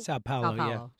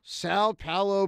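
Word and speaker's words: São Paulo: Today's question, do you like the Eagles opening their 2024 regular season on a Friday night in, how do you say this, Sao Paulo, Brazil? – Sao Paulo. – Sao Paulo, Brazil.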